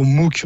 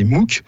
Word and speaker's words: MOOC: Oui, au MOOC, Mathieu. Alors, pas MOOC comme quand ça sent mauvais, pas genre tu MOOC de la bouche, mais MOOC, ça s'écrit M-O-O-C, c'est un acronyme.